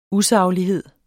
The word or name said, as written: usaglighed